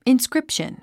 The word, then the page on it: inscription: inskrípʃən; インスクリプション